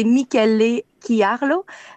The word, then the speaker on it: Michele Chiarlo: Et c'est Michel Chiarlo. Ben, dans le fond, les CH se prononcent comme un K. Donc, c'est Michele Chiarlo, c'est une maison vraiment qui a été établie, c'est une propriété familiale qui a été fondée en 1956.